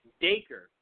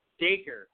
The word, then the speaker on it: Dacre: Yeah, because I thought it was I thought you pronounced it the way it was spelled. And I'm like, what is the E for? Just take the E off. But it's Dacre Montgomery.